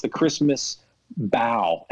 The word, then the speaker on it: The Christmas Bow: It's actually pronounced The Christmas Bow, and it's a Christmas movie about the high seas and seafaring.